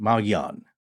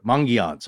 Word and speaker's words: Mogollon: And as we said, modern residents now pronounce it Maoyan or Mangyan. – Sometimes you hear a little bit of an N in there.